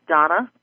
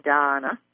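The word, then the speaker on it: Dahna: My name is Donna Fox. It's spelled D-A-H-N-A. I know that some people don't like to pronounce that with a Donna, but it's D-A-H-N-A, Donna.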